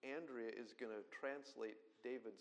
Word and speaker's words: Andrea: Not dear, dear, tight friends, but Andrea, there we go, that's the Spanish pronunciation, that's Andrea.